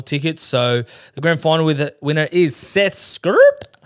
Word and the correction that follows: Skerritt: He did 1,068 points, 29 predictions. – Is that how you say it, mate? Is that how you say his last name? – Surely it's Skerritt. – Seth Skerritt. – It's Seth Skerritt.